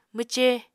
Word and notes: mwce: Apatani

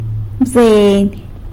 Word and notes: bzeiny: sanlucasquiavini

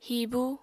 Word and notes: hibu: Apatani

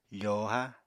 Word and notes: fòohá: apatani

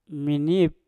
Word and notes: minip: Mpur

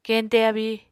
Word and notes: keñte abíi: Apatani

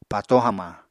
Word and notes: pató hamañ: Apatani

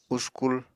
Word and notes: uskul: Apatani

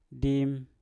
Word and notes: dim: Mpur

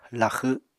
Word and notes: laxw: apatani

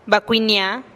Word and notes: bakuinihia-a: TdVZ

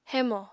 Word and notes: hémò: apatani

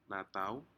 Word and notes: natau: Aneityum

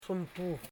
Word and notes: sumtu: sum.tu

